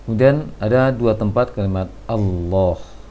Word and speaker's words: Allah: Nah, karena kalimat Allah ini yang sering salah dalam praktek kaum muslimin, terbaca Allah, Allah, seharusnya Allah. Qul huwallah, huwallah. Jangan Allah, tapi huwallah, Allah, Allah. Jangan Allah. Karena Allah itu ada kebiasaan pengucapan dari bahasa Indonesia barangkali Allah, seharusnya Allah, mengarah ke O sedikit.